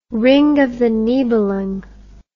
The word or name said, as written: Ring of the Nibelung